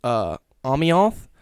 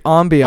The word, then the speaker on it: ambiance: In it, you used the word ambiance, but you didn't pronounce it properly. – We're ambiance. – Not French, dude.